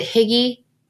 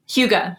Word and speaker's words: hygge: Talk about, is it Higgy? – Hygge. – I always say it wrong. Hygge. – It looks like Higgy though.